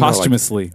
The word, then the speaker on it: posthumously: How do you say it? – Posthumously. – humorously. – Post. Yeah. – I never learned that word. How to say that word. But you posthumously.